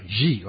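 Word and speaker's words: Xi: It's spelled X-I, and that's a Chinese word. You sort of pronounce it from the back of your throat, G, like that.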